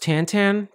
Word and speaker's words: Tintin: He actually was really pretentious about it, and he was like, it's actually pronounced Tantan.